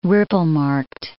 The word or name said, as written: ripple-marked